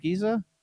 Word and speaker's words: Giza: Is that how you pronounce it, Giza? – That's right.